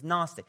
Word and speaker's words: Gnostic: You spell it with the silent G. It's Gnostic, but it's not, it's silent, so it's Gnostic.